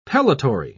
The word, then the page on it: pellitory: British English